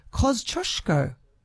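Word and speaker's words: Kościuszko: As a person of Polish heritage, it was great to hear Tristan from The Bomb give Kosciuszko, that's how I'm pronouncing it, its correct pronunciation, Kosciuszko. Leon from Morton's Creek, thank you for that text. You know, I heard it when Tristan said it, and I thought, oh, bless, perhaps they've said that incorrectly. I'm not going to correct them on air. But you're telling me Tristan's doing the correct Polish pronunciation.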